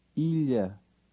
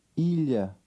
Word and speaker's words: Ilha: Ilha. Ilha.